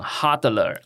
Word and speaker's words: HODLer: 当然这个用币圈的发音要讲 hodler、你知道吗？ – 知道，就是 hodl 这样 hodl、因为有一天有一个人拼错字、大家后来就一直笑他、就叫 hodl，那 hodl 加 er hodler、然后以及、也是 NFT 的算收藏家、这个文化观察者还有实验家还有这个创作者，你的下一步的计划大体是怎么样？